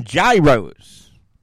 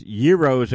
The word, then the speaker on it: gyros: So I did a radio show one time where we had a Greek restaurant on, and I kept saying gyros. And the whole show, I'm saying gyros, gyros, gyros. And the guy finally corrected me. He said, no, it's gyros. So that is how I learned how to pronounce that.